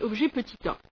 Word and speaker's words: objet petit a: Donc ça s'écrit objet A, donc A comme un A minuscule, simplement ça se lit objet petit a.